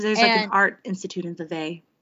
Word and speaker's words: Vevey: The world record for the gathering of Charlie Chaplin lookalikes was held in Vevey, Switzerland. There were 662 Charlie Chaplins there. – It's actually pronounced Vevey, and the only reason I know that is because Laurie says that in the Little Women movie.